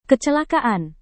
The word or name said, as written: kecelakaan